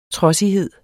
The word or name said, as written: trodsighed